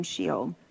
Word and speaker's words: Sheol: And if I make my bed in Sheol, is that how you say it? – Yeah.